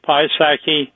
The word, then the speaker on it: Piasecki: I want to make sure I'm saying it correctly. The Piasecki Helicopter Corporation in Morton, PA. Am I saying that correctly? – That is right. Piasecki, and he developed.